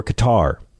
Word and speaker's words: Qatar: He spent 6 months loading planes at an airbase in Qatar, or Qatar, if you swing that way.